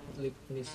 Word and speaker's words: Leibniz: Misalnya kalkulus dulu itu kan salah satu yang mengembangkan Newton dan siapa namanya Leibniz di saat Leib, Leibniz ya, bacanya hmm.